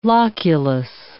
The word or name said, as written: loculus